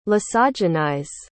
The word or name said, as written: lysogenize